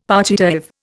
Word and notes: partitive: Indian

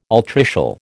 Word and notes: altricial: American English

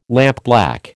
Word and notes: lampblack: American English

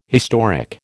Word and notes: historic: American English

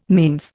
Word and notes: manes: Indian

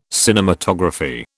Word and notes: cinematography: British English

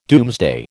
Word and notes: Domesday: American English

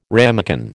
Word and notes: ramekin: American English

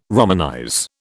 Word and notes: romanize: British English

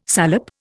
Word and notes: salep: Indian

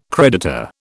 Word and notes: creditor: British English

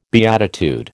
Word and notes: beatitude: American English

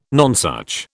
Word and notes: nonsuch: British English

